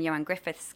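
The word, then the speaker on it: Ioan Gruffudd: And it's basically a sort of, you know, edge of your seat thriller based around two characters, my character, Laura Nielsen, and Johan Griffith's character. – Very good pronunciation there of Johan's name. As a Welsh person, I can vouch that's an excellent pronunciation of Johan Griffith.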